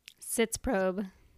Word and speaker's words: Sitzprobe: Sitzprobe. Sits. I always want to say spits because there's a P in it.